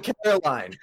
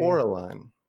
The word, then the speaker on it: Coraline: I was searching Caroline. Yeah. Watching the movie. Everyone says her name wrong, and it completely justified.